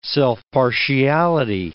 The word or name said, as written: self-partiality